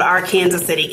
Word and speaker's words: Arkansas City: So I grew up in a small town south of Wichita, Kansas, called Arkansas City, Kansas, spelled like Arkansas, but said like Kansas.